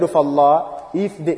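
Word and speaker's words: Allah (الله): Now this, the word of Allah, the word Allah, usually the proper pronunciation of it is full mouth. It must be pronounced full mouth.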